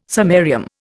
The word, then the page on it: samarium: Indian